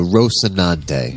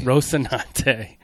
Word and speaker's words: Rocinante: The Rocinante. – Rocinante.